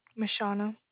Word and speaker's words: Mishauna: Mashana. – How do you spell that? – M-I-S-H-A-U-N-A.